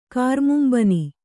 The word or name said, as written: ಕಾರ್ಮುಂಬನಿ